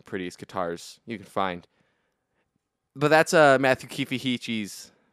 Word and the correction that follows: Heafy: I said that wrong. Matt Heafy of The Way of Trivium.